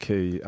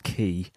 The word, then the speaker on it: quay: Yeah, it was an episode of Doctor Who that taught me that it was pronounced Key, and I think for me, even more embarrassingly, Tom, it was 2013.